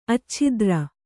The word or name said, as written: ಅಚ್ಛತ್ರಿ